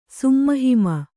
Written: ಸುಮ್ಮಹಿಮ

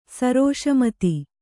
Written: ಸರೋಷಮತಿ

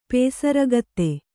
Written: ಪೊಸರಕ್ತ

